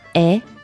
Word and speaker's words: est: Is is est. – Est. – Which is here E-S-T, mm-hmm. – But you pronounce est.